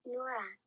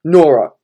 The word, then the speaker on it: Nwora: Nwora. – No. Hold on. – Nwora.